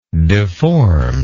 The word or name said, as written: deformed